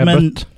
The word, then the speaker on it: LaBute: Regissert av eh, Neil Labut. Jeg vet ikke om det er riktig uttalt, men skrives Labute, da. – Og Lebut. – Med, Lebut, ja.